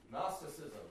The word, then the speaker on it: Gnosticism: Gnosticism, that's right. It starts with a G, but it doesn't help because the G is silent.